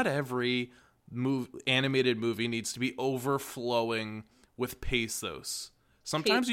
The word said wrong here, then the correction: pathos: pesos? The form of currency in Mexico? – I tried to say it correctly. I know what the word was. Pathos.